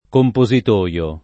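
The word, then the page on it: compositoio: italiano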